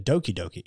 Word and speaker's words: Doki Doki: If you don't want to play it and you just want to watch somebody play it, Game Grumps did a Tokidoki playthrough, I think. Or is it Doki Doki? It's Doki Doki Literature. – Doki Doki, yeah. – Yeah. I've been saying it wrong this whole fucking time.